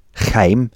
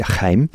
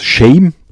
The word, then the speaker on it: Chaim: Und weil Chaim im Englischen oft als mit SH, also als Shame ausgesprochen werden könnte, wurde er in Eugene umbenannt.